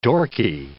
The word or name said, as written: dorky